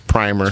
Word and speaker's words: primer: The word is primer. – Primer. – It's pronounced primer. It's primer.